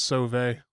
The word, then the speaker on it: Sauve: But another one that we've been doing a lot of is Brian Sauve. It's spelled Suave. – yeah. So I call him Suave. – You're looking for it, it looks like that. It's Sauve. But yeah, he has been taking just specific hymns.